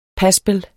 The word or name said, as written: paspel